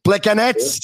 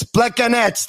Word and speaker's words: Plekanec: Am I pronouncing it correctly? Plekanec? – Yeah. – Fine. All right. Okay. We were told so many times it's Plekanec. That's how you got to pronounce it.